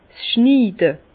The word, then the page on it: 's Schniide: Bas-Rhin; Herrlisheim